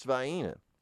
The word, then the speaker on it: Vienna: It's spelled Vienna, but it's pronounced, I assure you, it's pronounced Vienna.